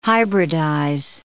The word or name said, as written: hybridize